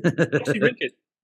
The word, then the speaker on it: Oxyrhynchus: Oxyrhynchus? How do you pronounce it? Oxyrhynchus.